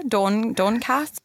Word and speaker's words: Doncaster: Doncaster? – I love the pronunciation.